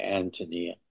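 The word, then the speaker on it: Ántonia: And she recommended to me My Antonia, and said, as pronouncing it correctly, Antonia, and said, this is a book about a girl.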